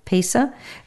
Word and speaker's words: Pesach: Pesah, it's spelled P E S A-C-H, right?